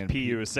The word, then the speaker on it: Pius: Everyone says it's Pius. I'm going to have trouble getting used to that. – It is Pius, and we've been saying it wrong the entire time.